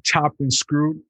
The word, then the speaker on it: Chopped and Screwed: And we are here with a lovely lady from Houston, Texas, down to the home of Chopped and Screwed.